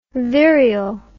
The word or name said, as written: virial